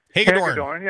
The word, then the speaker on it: Hagedorn: and then Hilgendorf, or whatever his name is, Hilgeborn, or Hagedorn. – I might get the name wrong. – Hagedorn. – Yeah.